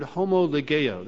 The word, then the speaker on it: homologeo: Well, this word confess is translated from the Greek word homologeo. That's spelled H-O-M-O-L-O-G-E-O.